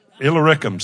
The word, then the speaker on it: Illyricum: Illyricum. Say with me, Illyricum. And that's a way to pronounce that.